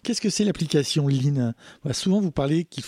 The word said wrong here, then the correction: Line: Alors je le prononce peut-être mal. Hein. – Ah, alors Line.